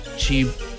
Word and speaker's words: chipotle: La forma correcta de decirlo es chipotle. Chipotle. No chipotle. No, es chipotle. Yo sí lo he dicho bien. ¿Tú qué crees? Fíjate, la palabra proviene del náhuatl chilpotli, que significa chile ahumado, compuesto por chile, y pocti, que es humo.